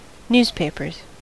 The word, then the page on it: newspapers: American English